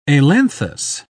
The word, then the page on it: ailanthus: British English